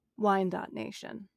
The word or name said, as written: Wyandotte